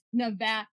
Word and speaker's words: Nevada: I'm sorry, there's one way to pronounce Nevada, and it is Nevada.